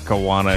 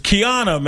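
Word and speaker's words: Kijuana: It's not Kiwana. – Kiwana. K-I-J-A. K-I-J-U-A-N-A. – She pronounced it Kiana, I think. – It's Kiana, man.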